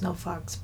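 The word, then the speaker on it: NOFX: Well, it's pronounced No Fucks, but No FX.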